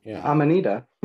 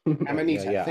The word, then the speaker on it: Amanita: I can never say it right. Amanchia, it's a mushroom. – Amanita. – Amanita, thank you.